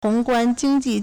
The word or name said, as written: hong2guan1jing1ji4